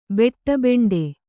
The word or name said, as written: ಬೆಟ್ಟಬೆಂಡೆ